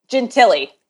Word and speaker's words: Gentile: Gentile.